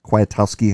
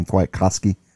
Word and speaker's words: Kwiatkowski: Nick Quiet. Kwiatkowski, I can never say his name, Kwiatkowski, the inside linebacker, he's good.